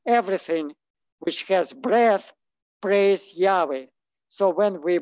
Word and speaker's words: Yahweh: And so, and it's to pronounce it, it's Yahweh.